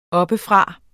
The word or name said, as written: oppefra